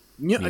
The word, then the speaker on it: Nguyen: Nguyen. – Okay, there you go. The G is silent, apparently.